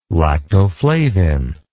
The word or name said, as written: lactoflavin